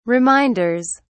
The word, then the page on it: reminders: British English